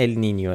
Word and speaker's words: ال نینیو: به هر حال اینو همین اول بگیم که اگه دیدید یه بار میگیم ال نینو یه بار میگیم ال نینیو، علتش اینه، ولی تلفظ درست اگه میخواین ال نینیو، ولی همه جا تو فارسی بچرخید کسی چی نمیشه ال نینیو، همه جا نوشتن ال نینو.